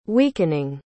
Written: weakening

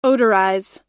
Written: odorize

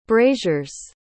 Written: brazier's

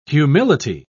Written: humility

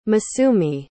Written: masumi

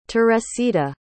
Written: teresita

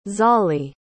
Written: zahlee

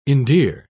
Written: endear

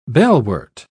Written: bellwort

